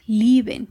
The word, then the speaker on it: lieben: Los verbos deletrear, Buchstabieren y amar, lieben. Y por supuesto el nombre del lugar, Wiesbaden. Todas estas palabras tienen el diptongo IE, que pronunciaremos como una I larga.